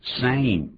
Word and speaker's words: same: The same as usual. The same as usual. – Same，不要念成了 Sam 啊，发就像刚才讲飞机的那个飞 A 的音，same 不要念成 Sam。